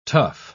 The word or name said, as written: tuff